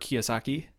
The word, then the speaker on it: Kiyosaki: That book by Robert Kiyosaki. – Mm-hmm. – He I like how you pronounce that the American way. – Kiyosaki.